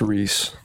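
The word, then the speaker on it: Rhys: It's pronounced Reese. Looked it up.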